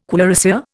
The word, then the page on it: cuirassier: Indian